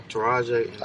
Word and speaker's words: Taraji: Acted like that in the movie with Taiji and. – Taraji? – Taraji.